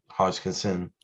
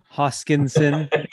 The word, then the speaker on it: Hoskinson: You can see here's a photo of Charles Hodgkins and Hodgkinson, Hoskinson.